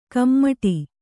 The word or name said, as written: ಕುಮುಟು